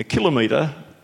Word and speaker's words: kilometre: If I might briefly explain there, I said to a number of these people, a kilometre is obviously the correct pronunciation because, as they pointed out there, there are more than 20 SI units that can have the prefix kilo for 1,000, and metre can have 22 different prefixes and always has the metre sound.